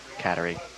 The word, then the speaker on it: Kateri: Well, it's Kateri, well, you're supposed to say Kateri. All right. It rhymes with battery, but I've heard it so many different ways talking to so many different people.